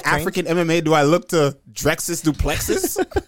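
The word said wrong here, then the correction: Dricus Du Plessis: Drykus Duplessis.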